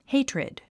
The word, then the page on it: hatred: héitrid; ヘイトレド